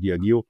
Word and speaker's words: Diageo: Ich habe gesagt Diageo, aber man spricht es offensichtlich anders aus. – Es geht beides, ja.